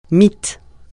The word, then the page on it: mite: francais